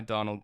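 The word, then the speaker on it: Domhnall: So we have, and I Googled it, and Donald Gleason has a great quote where he's like, you pronounce it like tonal, but with a D, and then there's an N in there just to mess with Americans.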